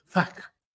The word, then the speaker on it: Flach: Se escribe F-L-A-C-H, Flach, pero lo pronuncian como sin la L, Fag.